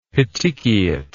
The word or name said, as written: petechiate